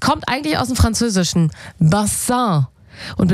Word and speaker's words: Bassin: Und naja, die Aussprache, die hat ein bisschen gehapert. Also statt Bassin wurde es irgendwann Baseng.